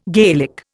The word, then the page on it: Gaelic: Indian